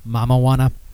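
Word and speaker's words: Mamajuana: And it's spelled, well, it's two words, but it's pretty much, it's spelled like marijuana, but it's Mama Juana.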